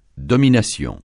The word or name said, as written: domination